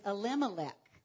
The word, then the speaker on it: Elimelech: Naomi was wife of Elimelech. I followed suit. I figured that I had always pronounced these names wrong, and I was. So his name is Elimelech.